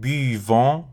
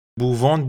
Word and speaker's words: buvons: ولی من مدرس هایی میبینم که میگن بووان بووه مثل فعل پوواخ که میگفتیم پووان پووه فعل بواغ هم میگن بووان بووه ولی بو نیست بیه بیوان